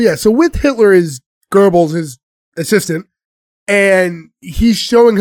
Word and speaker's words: Goebbels: What do we say his name Goebbels. – Is? Goebbels.